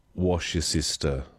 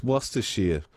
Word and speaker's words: Worcestershire: It's pronounced Wash Your Sister Sauce. Wash Your Sister Sauce. No, it's not. Isn't it Worcestershire? It's Worcestershire Sauce.